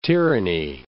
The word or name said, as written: tyranny